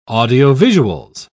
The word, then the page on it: audiovisuals: British English